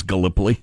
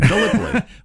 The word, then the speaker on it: Gallipoli: Yeah, except I wasn't 100% sure how to pronounce it. I thought it was Gallipoli. – So that's fun to say. Let's say it together. Gallipoli.